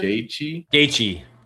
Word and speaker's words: Gaethje: Gaethje. – Justin Gaethje.